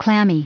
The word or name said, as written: clammy